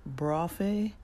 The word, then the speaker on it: Braufe: His name was Eldon Yoder, E-L-D-O-N, Yoder, and the title of the video was Brophe Among the Amish Live with Eldon Yoder, and that word is spelled B-R-A-U-F-E.